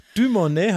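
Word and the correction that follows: Dumornay: habe ich sie genannt und es ist Dumoni wenn ich es jetzt richtig mir gemerkt habe.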